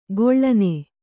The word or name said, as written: ಕಳನಿ